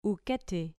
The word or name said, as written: uketi